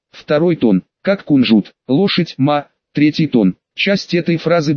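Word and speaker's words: «ма» (马): Это вот этот вот, он читается как «ма». Но не то «ма», которое «мама», это другое «ма». Тоны, помните, как фамилия мамы Вэйлань, уточнила я на уроке.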